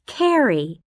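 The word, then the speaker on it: Kerry: It's D-E-N-A. – 3. – Hello. My name is Carrie. – Kelly? – No, it's Carrie. – How do you spell that? – K-E-R-R-Y. R-R-Y.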